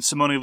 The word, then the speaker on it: Simone: And Simone Luciani, by the way, we were corrected on some of our Italian name pronunciation. It's Daniele Tascini. There are three syllables. Similarly, there are three syllables in Simone.